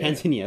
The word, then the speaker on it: Tanzania: is Tanzania, or Tanzania, yeah, I believe that's Tanzania. – Tanzania. – Tanzania. – Tanzania, that's how you say it.